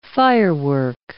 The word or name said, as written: firework